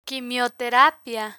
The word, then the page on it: quimioterapia: spanish